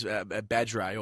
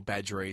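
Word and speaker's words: Badree: Speaking of pronunciations, Badray, or Badry as he's routinely called, he's also back.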